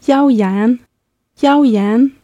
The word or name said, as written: yaoyan